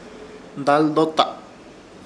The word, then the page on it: Daldota: Ishir Chamacoco